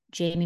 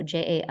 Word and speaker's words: Jaime: It's at Jamie Messina, and my first name is spelled like Jaime, so Jaime Messina.